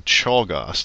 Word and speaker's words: Czolgosz: He was shot by an anarchist named Leon Czolgosz. That's how you pronounce that last name.